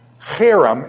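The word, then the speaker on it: herem: Harem, it's pronounced harem. It's a hard H, okay?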